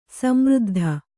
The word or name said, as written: ಸಮೃದ್ಧಿ